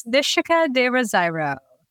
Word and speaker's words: Nishika de Rosairo: It's Nishika de Rosairo.